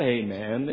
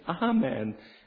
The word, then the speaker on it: Amen: It's not Amen, it's Amen. The Greek word is Alpha, and it's pronounced Ah. It's Amen. I suppose it doesn't really matter at the end of the day, but matters to me.